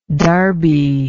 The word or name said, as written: darby